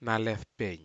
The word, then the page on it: nalefpei: Aneityum